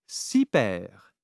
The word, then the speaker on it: super: Now, say super. Then round your lips as you say the first syllable.